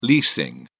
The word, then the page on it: leasing: British English